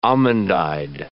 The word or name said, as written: almond-eyed